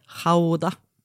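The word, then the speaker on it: Gouda: Jag tänkte också igår på när jag såg på spåret att goda heter Hauda på holländska. – Alltså när man, gud, fan, störig kille personlighet. – Ja. – Gå in, hej, jag ska vi köpa 2 hektar Hauda.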